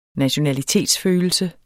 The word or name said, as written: nationalitetsfølelse